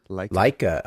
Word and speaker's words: Leica: but what is it, the Leica notebooks? – Leica. – Okay, thank you.